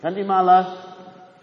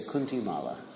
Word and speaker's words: kanti mala: Kanti mala is the neck, the mala around the neck. And in America, they don't know how to pronounce it. They say kunti mala.